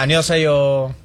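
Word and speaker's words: annyeonghaseyo (안녕하세요): Hola, annyeonghaseyo. Pero sí, cantadito.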